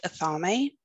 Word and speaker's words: athame: So you have your wand and you have your athame. You may hear that pronounced athame. According to Google, it's pronounced athame, but I don't know.